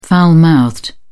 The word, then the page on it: foul mouthed: British English